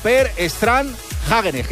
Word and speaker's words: Per Strand Hagenes: La ha ganado un noruego, Per Strand Hagenes, que esto se pronuncia así literal, porque he llamado... – Como los helados, ¿no? – He llamado a Noruega y se me ha puesto un salmón. Me ha dicho que era así.